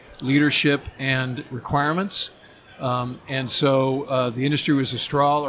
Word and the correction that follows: Austal: or am I pronouncing that right? Who make Austal?